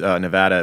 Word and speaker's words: Nevada: It's when like the entire world mispronounces Nevada, it bothers me. But it's just like it's people that should know by now, people that have, you know, either work in Vegas or like frequent Nevada constantly and they still call it Nevada.